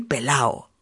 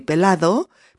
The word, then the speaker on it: pelado: También se dice estoy pelao. Estoy pelao. Lo mismo, ¿eh? Sería estoy pelado, pero como se dice realmente, es estoy pelao.